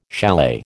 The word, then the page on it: chalet: American English